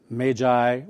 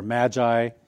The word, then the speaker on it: magi: And it's the word, it's pronounced in different ways, but it's magi or magi.